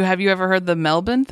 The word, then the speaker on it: Melbourne: Isn't, I feel like, so it's like when you try to say Toronto, it's Toronto. or like that's how they say it. And Melbourne is Melbourne, as far as I understand.